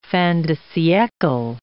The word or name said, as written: fin de siecle